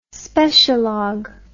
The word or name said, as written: specialog